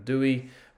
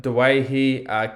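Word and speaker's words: Doueihi: Those who are new to the podcast may know him as Adam Dewey. We prefer the traditional Lebanese pronunciation of Dewahi.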